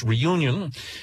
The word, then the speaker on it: Réunion: Réunion. Which, of course, is pronounced in English, Reunion.